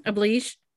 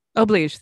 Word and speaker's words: oblige: Oblige. – Oblige. Thank you. I can never pronounce it right, because honestly, it looks like oblige.